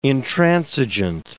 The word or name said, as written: intransigent